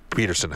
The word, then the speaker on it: Pettersson: Well, that's because Jack asked Elias Peterson, how to pronounce his name. And he told him it was Peterson.